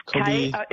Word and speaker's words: Kobi: Kobe Yamada, is that, or... – Yes, Kobe. – It's spelled differently. He is Canadian, but Japanese surname. K-O-B-I, and his surname is Yamada.